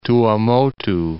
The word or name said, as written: Tuamotu Archipelago